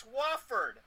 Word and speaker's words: Wofford: It's Wofford.